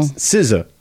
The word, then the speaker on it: SZA: And then SZA, isn't she, is that spelled like skazizzas? You know? – What? – Like, you look at it on the screen, you're like, is that skazizza? – No. SZA.